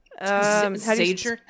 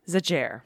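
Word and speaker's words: Szájer: It led to the resignation of Hungary's prominent anti-LGBTQ politician, Joseph, I would say, Zahir? You're going to help me with this name. What would you say? – Zager? Spell it for me. – S-Z-A-J-E-R. – Zager. – Joseph Zager. We're going to call him Joseph Zager.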